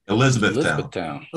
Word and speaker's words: Elizabethtown: And now there's a 12-field complex in Elizabethtown, right? I said that right? – Elizabethtown.